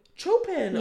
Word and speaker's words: Chopin: Oh yeah, well, I was going to say, I've been listening to a lot of Chopin. Is that how you say it? – Chopin? – Chopin, the classical. – Chopin, yeah.